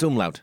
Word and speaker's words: umlaut: Yeah, it's umlaut.